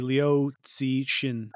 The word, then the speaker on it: Liu Cixin: But first I want to preface by saying it's a fictional theory from the novel, The Dark Forest, by Chinese author Liu Cixin, if I got that right. – That's a pretty good pronunciation. I'd say Liu Cixin.